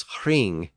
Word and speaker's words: hring: So a word like ring was hring in Old English, spelled H-R-I-N-G. And roof was hruf, H-R-U-F.